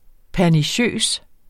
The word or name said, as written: perniciøs